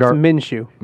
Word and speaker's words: Minshew: And Minshew, or how do I pronounce his name? Minshew. – Yep, it's Minshew.